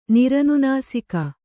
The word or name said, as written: ನಿರನುನಾಸಿಕ